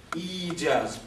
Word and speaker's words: İcaz: İcaz. İcaz. Bu çok önemli bir telaffuz gerektiriyor. İcaz.